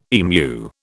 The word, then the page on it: emu: British English